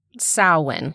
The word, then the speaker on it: Samhain: It's one of the Samhain, Samhain, Samhain. – Samhain. – Okay. That's what we're going with.